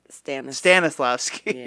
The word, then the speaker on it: Stanislavski: So that would have been, because that's also like a birthplace of like modern theater, like with, you know, Stratoslavsky and, did I say his name right? – Stanislavski. Yes.